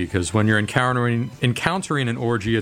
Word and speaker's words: Orji: Orgy? – That's how it's spelled. – O-R-J-I, Orgy.